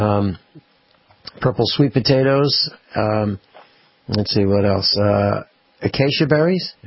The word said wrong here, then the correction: acai: Am I saying that right? A-C-A-I. – Yep. – Okay. – acai. – Acai, okay.